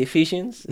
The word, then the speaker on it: Ephesians: The Ephesians, is that how you pronounce it? – Rose, is that correct? – Correct.